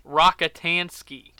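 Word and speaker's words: Rockatansky: Whoa, have I been pronouncing this fictional character's name wrong? Rankintowski? – Rakitansky. – Jesus, Rakitansky. I am.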